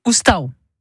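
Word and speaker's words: oustau: La maison en provençal, ça se dit oustao. – C'est parfait. Merci.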